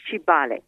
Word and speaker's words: Kibaale: It's spelled K-I-B-A-A-L-E. Okay. There is another city that is called Kabale that I've been involved in, but this one is Kibale.